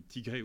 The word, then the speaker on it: Tigré: Vous avez donc une rébellion dans une province qui s'appelle la province du Tigré ou du Tigraï, selon la prononciation qu'on adopte.